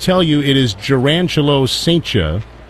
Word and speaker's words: Cijntje: Gerangelo Saintia's last name is spelled C-I-J-N-T-J-E. Pronounced Saintia.